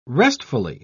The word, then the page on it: restfully: British English